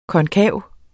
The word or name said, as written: KONKAVE